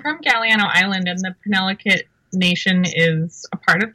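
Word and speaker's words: Penelakut: Penolakut Nation.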